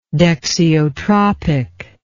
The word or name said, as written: dexiotropic